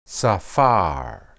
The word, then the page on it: saphar: hebrew